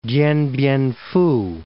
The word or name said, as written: Dien Bien Phu